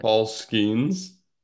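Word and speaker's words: Skenes: Paul Skeens.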